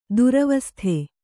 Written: ದುರವಸ್ಥೆ

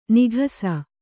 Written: ನಿಕಸ

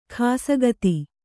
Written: ಖಾಸಗತಿ

